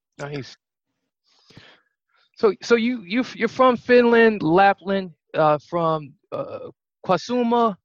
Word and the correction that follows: Kuusamo: I pronounced that right? – Yeah, almost there. It was Kuusamo.